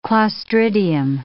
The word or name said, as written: clostridium